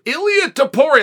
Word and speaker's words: Ilia Topuria: I called Zach Candido yesterday and said, How do I pronounce Ilia Topuria's name? and he sent me a phonetic spelling and an, an audio clip. Ilia Tapori, that's exactly right.